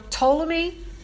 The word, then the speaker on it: Ptolemy: And so those were Ptolemy, the king or the empire. It was the Ptolemy Empire. It's the P is silent, the Ptolemic Empire, and the Seleucus or Seleucus Empire.